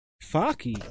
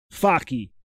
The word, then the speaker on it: Focke: The Charlotte Hornets suspended radio broadcaster John Faki Focky. Oh my gosh. Okay. – How you spell it? How you spell it? – F-O-C-K-E. Focky. – I think that's Fock. – Fock. Just Fock? – Yeah.